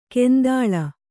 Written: ಕೆಂದಳ

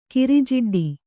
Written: ಕಿರಿಜಿಡ್ಡಿ

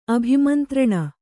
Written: ಅಭಿಮಂತ್ರಣ

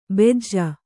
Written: ಬೆಜ್ಜ

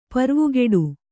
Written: ಪರ್ವುಗೆಡು

